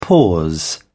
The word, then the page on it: pause: British English